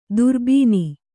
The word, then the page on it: ದುರ್ಬೀನಿ: durbīni